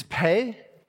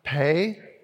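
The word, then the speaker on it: Pe (פא): And the letter is Pe. Pronounce that with me, everyone. Pe.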